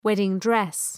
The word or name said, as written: wedding-dress